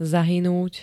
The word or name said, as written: zahynúť